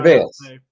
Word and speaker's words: travails: Baker Mayfield's latest tra- tra- travels travels. How do you say that? I wrote a word in my intro that I forgot. – Travails. Travails. Tra- travails.